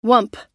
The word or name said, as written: whump